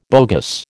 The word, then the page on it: bogus: American English